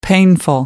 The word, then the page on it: painful: American English